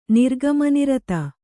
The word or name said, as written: ನಿರ್ಗಮನಿರತ